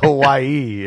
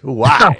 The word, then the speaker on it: Huawei: It's not Hawaii. It's just Huawei.